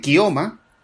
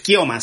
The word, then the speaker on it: Keoma: Esas son algunas de las cosas más interesantes que he visto y también he estado viendo, vi un spaghetti western que es Kioma, no se deletrea que K-E-O-M-A, e Keoma, se diría Keoma, pero es Kioma, se, se pronuncia esta película de Enzo Castellari, que es una película ya hecha pues con las cenizas del Spaghetti Western, ¿no?